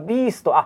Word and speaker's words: ビースト: うんビーストってあの雑誌でしょ、はい、あビーストあこれああこれビストじゃなくてビーストって読むのね。